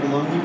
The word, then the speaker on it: Bologna: Am I saying that right? Bologna? – Bologna, yeah.